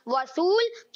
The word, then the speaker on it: وصول: اور وصول صحیح تلفظ ہے وصول غلط تلفظ ہے